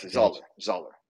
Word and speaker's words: Zahler: And the director would be Craig Zahler. I'm not sure how to pronounce his name. – Yes, Zahler.